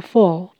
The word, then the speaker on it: full: In full bloom，我们先看到 in bloom 这个片语是指花朵盛开，那中间加一个 full，有充分的、完全的的语义。所以，in full bloom 就是指开得很旺。请留意 f u l l 的发音，u 在这里是发我我的短音，l 稍微舌头抬高，舌根收紧。